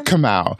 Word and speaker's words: Kamau: That's Kamau Bell. – Kamau Bell. Whatever. Shut up. How do you say his name again? – Kamau. – Kamau. Ring it.